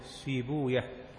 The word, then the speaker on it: سيبويه: وإسحاق بن إبراهيم هو الحنظلي مولاهم المروزي المشهور عند العلماء بابن راهويه، ويقال راهويه ويقال راهوية، فالمشهور في نطق أهل الحديث راهوية زنجوية سيبوية، وأما أهل اللغة فراهويه وسيبويه وزنجويه وهكذا، وهي من ألفاظ العجم الألفاظ الفارسية التي عربت.